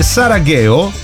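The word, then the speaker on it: Sarageo (사랑해): Ad esempio, ci scrivono, si legge Sarageo, scrivo ti amo in coreano.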